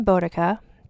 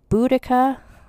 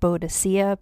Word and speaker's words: Boudica: I'm going to pronounce her name Boudica, because that is what how I think it's said. Although some people pronounce it Boudica. For a long time, people thought it was called Boadicea, but that was based on just misreading somebody's writing, where they thought a C was an E at the end.